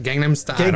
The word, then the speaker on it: Gangnam Style: And this is during the time of Gunman Style. Is that how you say it? – Yeah, I think so, yeah. Yes. Gangnam Style.